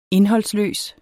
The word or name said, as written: indholdsløs